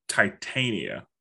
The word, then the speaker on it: Titania: Tatiana. – Yeah. – Her name is Titania. – Yeah. – Titania. You're right.